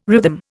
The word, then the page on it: rhythm: Indian